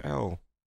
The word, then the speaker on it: Elle: It's L. – E-L-L-E.